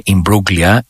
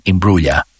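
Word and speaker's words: Imbruglia: Δεν είναι Ιμπρούγλια, είναι Ιμπρούλια. Κάπως έτσι, στο περίπου.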